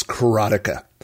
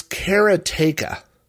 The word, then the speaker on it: Karateka: Now, speaking of pronunciations, everybody I know has pronounced the name of this game as Karateka.